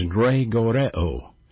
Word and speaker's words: gregoreo: The Greek word for vigilant is gregoreo. Gregoreo, vigilant. G-R-E, pronounced A-Y, G-R-E, G-O-R-E-O. Excuse me.